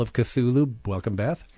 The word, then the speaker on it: Cthulhu: When you see C-T-H-U-L-H-U, how do you pronounce that? Cthulhu is where most people seem to settle.